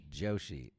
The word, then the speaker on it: Joshi: Joshi.